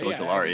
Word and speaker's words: Ojulari: but a safer player in Ojulari. I'm saying that wrong. Jordan, I need your help. – I think that's right. Azeez Ojulari. Yeah, that sounds right.